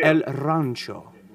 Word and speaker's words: El Rancho: El Rancho. Tip of the top of the tongue to the T. That's all you have to do to roll your R's.